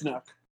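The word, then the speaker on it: Snoke: Wait, is it Snook? – It's Snook. I just said it wrong.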